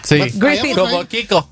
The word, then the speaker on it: Griffith: Como Griffith. – Griffith. – Cada vez que ponga la TH como D, como THE. – A ver, ¿cómo se dice, pues? – Griffith.